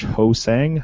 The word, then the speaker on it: Ho-Sang: Josh Ho-Sang, hope I'm saying that right. – Yep, you are, and I was hoping you'd Ho-Sang me.